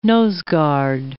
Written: noseguard

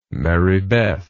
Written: marybeth

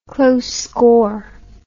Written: close score

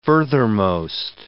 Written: furthermost

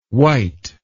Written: wyte

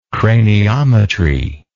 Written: craniometry

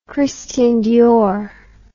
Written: Christian Dior

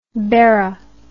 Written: Berra

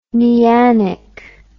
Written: neanic

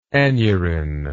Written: aneurin

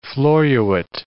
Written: floruit

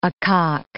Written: acock

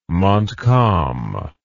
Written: Montcalm

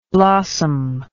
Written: blossomless

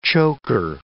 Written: choker

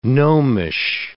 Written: gnomish

